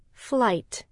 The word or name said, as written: flight